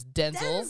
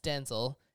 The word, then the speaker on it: Denzel: Oh, speaking of Denzel Washington's actual pronunciation is Denzel. – Denzel. – It's Denzel. And we've all been saying it wrong.